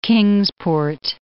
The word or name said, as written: Kingsport